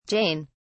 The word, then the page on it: Jain: British English